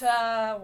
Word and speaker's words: Atlas: It's Atlas. Atlas. – Yeah, it's the got bracket. – It. They said in chat how to say their name. – Oh, okay. Atlas. – The L's on the side are like pizzazz.